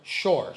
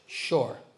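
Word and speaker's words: Shor (שור): So Hebrew, how do you say an ox? Anybody? Ox? Shor. Shin, vav, resh. Shor, right?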